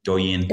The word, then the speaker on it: Douyin: did I say that right? Douyin. – Douyin, that's TikTok's Chinese version.